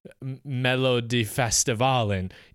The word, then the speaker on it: Melodifestivalen: So it's actually still kind of a big deal to do well in Melodifestivalen, even if you're not a winner.